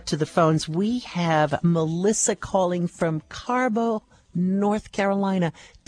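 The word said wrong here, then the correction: Carrboro: It's actually Carborough.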